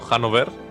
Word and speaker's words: Hanover: Y sí, luego, bueno, pues vamos a tener a Dinastías, de nuevo a la casa Hanover, Hanover, depende de cómo pronunciéis. – Hanover, con... como el pub irlandés.